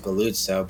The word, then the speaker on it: Galluzzo: I guess it's supposed to be pronounced Galluzzo, but I've always pronounced because it's pizza.